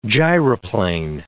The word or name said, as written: gyroplane